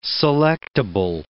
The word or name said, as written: selectable